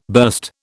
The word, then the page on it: burst: British English